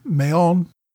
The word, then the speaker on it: Mahon: It should have been Mahon.